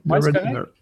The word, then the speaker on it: Redeemer: Je vais sûrement mal le prononcer puisque je ne suis pas bon avec les films dans les titres en anglais. Redeemer. Redeemer. – Correct. Redeemer. The Redeemer.